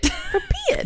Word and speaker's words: Pripyat: Pripyat. – Pripyat.